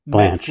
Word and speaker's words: Blanche: So Todd Blanche, is that his name? Am I pronouncing his name correctly? – Blanche, yes.